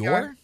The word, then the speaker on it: Dior: The D-I-O-R, is it Dior? Is that how you say Dior? – Dior? Yeah, it's Dior.